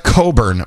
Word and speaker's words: Cockburn: It's pronounced Coburn, by the way. It's spelled Cockburn, but I do believe they say Coburn, if I'm not mistaken.